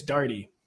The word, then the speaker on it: Darty: Kind of like my last name, even though I don't know how many times I need to say it, it's Darty. It's real easy, too. I mean, just look at it. It's very sensible pronunciation.